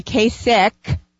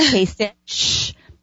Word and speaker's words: Kasich: And Kasich, and you'll be really cool people, listener friends, if you say Kasich instead of Kasich. Although it looks like it's spelled Kasich, it's pronounced Kasich.